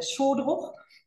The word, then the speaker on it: Szodruch: Mein Nachname ist äh, Schodruch, wird ein bisschen kompliziert geschrieben mit SZ, SZO am Anfang und SCH gesprochen.